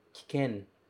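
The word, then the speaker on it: kiken (危険): A palavra é kiken, kiken. E o kanji de abunai aparece novamente aqui. Só que nesse kanji, nessa palavra, o primeiro kanji se pronuncia ki.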